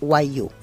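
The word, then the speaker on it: wagyu: Se escribe W-A-G. ¿Cómo era, Eli? – G-Y-U. – Y-U. – Exactamente. – Y guayú. Se pronuncia guayú.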